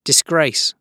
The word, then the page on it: disgrace: British English